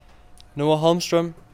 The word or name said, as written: Noah Holmstrøm